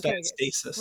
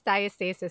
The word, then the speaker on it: diastasis: How do you pronounce it again? Because everyone, there's different ways to say it, by rectus diastasis. – Rectus diastasis. Signs of rectus diastasis.